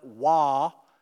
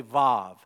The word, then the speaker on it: Vav: I know that most English translations spell it Wah, W-A-W. But if you want to sound like a real Hebrew, and I think most of you do, then you don't say Wah, you say Vav.